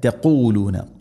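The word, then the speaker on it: تقولون: അം തഖൂലൂന, എപ്പോഴും പറയാറുള്ളത് പോലെ തന്നെ തഖൂലൂന, ഖാഫ്, ഖാഫിൻ്റെ ഉച്ചാരണം ശ്രദ്ധിക്കണം. തൊണ്ടയുടെ മുകൾ ഭാഗവും കഴിഞ്ഞ് ചെറുനാക്കിൻ്റെ ആ ഒരു ഭാഗം.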